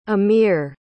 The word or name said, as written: emyr